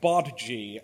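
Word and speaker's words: Badguy: He hands them a business card, and his name is Dominic Bad Guy, which he explains is pronounced Bodji, and that it's French, and that it actually means good man.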